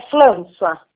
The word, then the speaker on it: François: Como é que pronuncia? François mesmo? – É François.